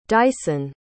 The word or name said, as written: dyson